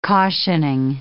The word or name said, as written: cautioning